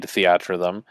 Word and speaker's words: Theatrhythm: And for those of you who haven't played Theatrhythm, as it is pronounced.